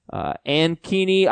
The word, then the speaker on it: Ankeny: Ankeny, Iowa? Ankeny? A-N-K-E-N-Y? – Sure. Sounds great.